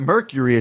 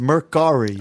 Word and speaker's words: Mercari: It's not Mercury. It's Mercari.